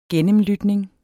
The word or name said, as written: gennemlytning